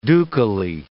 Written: ducally

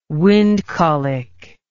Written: wind colic

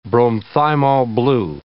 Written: bromthymol blue

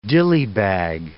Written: dilly bag

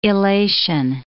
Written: illation

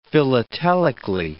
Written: philatelically

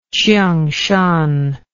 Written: chiungshan

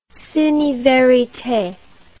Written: cine verite

